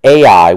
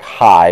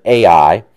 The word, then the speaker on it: Ai: between Bethel and Ai would be a better pronunciation. If you see an H there, it sounds like high, but Ai.